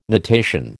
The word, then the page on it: natation: American English